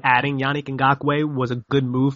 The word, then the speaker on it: Ngakoue: There's two ways to say this, Ngakoue or Nku. How do you say his name, Nick? – I just call him Yannick Ngakoue. – There you go, Yannick Ngakoue.